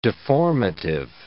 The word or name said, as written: deformative